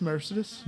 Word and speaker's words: Mercedes: Yeah, spelled like Mercedes, but it's Mercedes. – Mercedes. It's spelled like Mercedes. – Yeah, it's the original pronunciation.